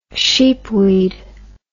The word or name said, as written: sheepweed